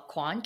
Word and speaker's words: Kwan: My last name is spelled Kwan, K-W-A-N.